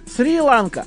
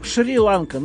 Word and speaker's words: Шри-Ланка: Мне вот Ванька написал сейчас, что по-английски пишется Сри-Ланка, а по-русски читается как Шри-Ланка.